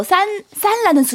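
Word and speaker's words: 산 (三): 네 광동 지역에서 또 산라는 숫자 3. 산란수대 싫어하고요. 그리고 사실은 중국 사람들 자체가 산란수대를 좋아하지 않습니다. – 3. – 네, 3. – 맞아요. – 3이라는 숫자가 중국어 발음 산.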